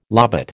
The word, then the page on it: lobate: American English